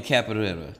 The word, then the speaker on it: capoeira: But, I ain't know nothing about no damn capoeira. Well, how you say it? – Capoeira. – Yeah, capoeira.